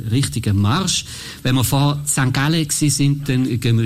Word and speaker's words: St. Gallen: Ja, für alle in Bhutan oder irgendwo, wo es hört, so spricht man St. Gallen aus. St. Gallen gefällt allen.